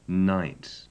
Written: night

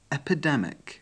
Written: epidemic

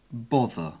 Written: bother

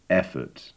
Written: effort

